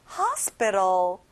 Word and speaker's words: hospital: 병원, hospital. 여기도 역시 T 발음이 안 나네요. hospital.